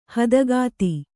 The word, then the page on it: ಹದಗುದಿ: hadagudi; Kannada